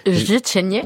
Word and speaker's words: życzenie: Życzenie.